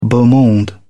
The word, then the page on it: beau monde: British English